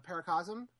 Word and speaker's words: Paracosm: Paracosm, I think is that how you pronounce it? – Yes.